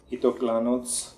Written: Itoklanoz

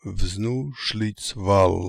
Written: Vznooshlitzval

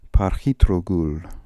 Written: parkhitrogool

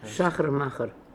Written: Shachermacher

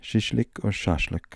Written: shashlik

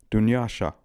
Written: Doonyasha

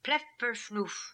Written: Plef Perf Noof

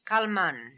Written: Kalman

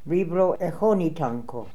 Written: Vibroechonitanko

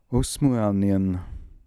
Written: Osmooalnian

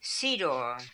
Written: Sidor

